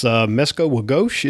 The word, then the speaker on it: Mesko Wagosh: And Denny, I'm probably going to mispronounce this, but it's Mesko Wagosh. Is that correct, Denny? – That's pretty close. – Well, correct me. – No. You're right on. It's Ojibwe, and it means red fox.